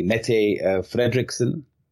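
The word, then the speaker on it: Mette Frederiksen: Mette uh, Frederiksen.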